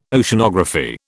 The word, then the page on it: Oceanography: British English